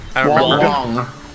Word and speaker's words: Wong: Is it Wong? Am I saying that right? Is it... – Wong. – Yeah, okay, so it's Wong.